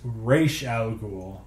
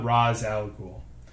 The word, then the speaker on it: Ra's al Ghul: According to Denny O'Neill, the character's creator, and every DC animated project since Batman the Animated Series, his name is pronounced Ra's al Ghul, not Ra's al Ghul.